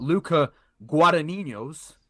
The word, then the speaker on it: Luca Guadagnino: In terms of the winners, at runner up, we've got Luca Guadagnino's Bones and Nicely all.